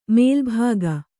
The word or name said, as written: ಮೇಲುಭಾಗ